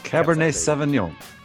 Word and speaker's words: Cabernet Sauvignon: But Rogue Admiral's Cabernet Sauvignon. How do you say that? It is a cab. It's a cab. – It's a cab. – It's a cab. – Cabernet Sauvignon.